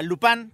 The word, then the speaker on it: Lupin: Se escribe Lupin, ¿sí? pero se pronuncia Lupin porque se supone que es un personaje francés.